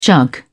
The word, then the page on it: jug: British English